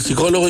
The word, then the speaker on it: psicólogo: Y el psicólogo le dice, señor, primero no se pronuncie la P.